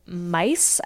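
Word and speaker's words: Myss: So this this book is written by Dr. Caroline Mice, I think is how you say her last name.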